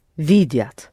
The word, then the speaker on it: видят: You pronounce it soft because it's ят. Я that you have. Say it again, please. – Они видят.